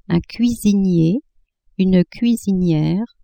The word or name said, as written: cuisinier, cuisinière